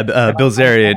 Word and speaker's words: Bilzerian: And I'm like, 1,000 times one more manly than Dan Bilzerian, or however you say his name. – Yeah, Bilzerian.